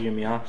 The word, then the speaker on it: Yirmiyahu: Sometimes it's called Yirmiya, sometimes it's called Yirmiyahu. – Like Yeshaya and Yeshayahu. You can call it any of them and you would be correct in your pronunciation.